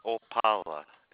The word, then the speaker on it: Opala: David Opala, and that's spelled O-P-A-L-A.